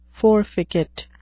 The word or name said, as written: forficate